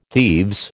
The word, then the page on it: thieves: American English